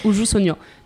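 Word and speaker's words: Uju Sonyo (우주소녀): Et en fait, c'est donc WJSN. Et là, j'étais genre, ah, oh, mais ça se prononce comme ça. Je connaissais les lettres, on va dire, voilà. Mais je savais pas que ça se prononçait Uju Sonyo.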